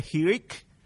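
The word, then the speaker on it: hiriq: The hirik of the Hebrew, which is just one dot and pronounced with an E sound, bereshit, E sound, just that dot, one tittle, the dot will never fail, will never disappear.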